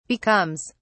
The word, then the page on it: becomes: British English